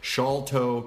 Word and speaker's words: Sharlto: So if you've ever seen his name and you're like, is it Sholto Copley? No, Sholto, shawl, like you put on a shawl. Sholto Copley, Sholto Copley.